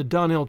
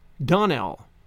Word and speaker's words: Donnell: I'm sorry, faltered Anne, vainly trying to recollect any incident of the morning connected with the Donnell children. Clarice Elmira told me that you pronounced our name Donnell. Now, Miss Shirley, the correct pronunciation of our name is Donnell, accent on the last syllable.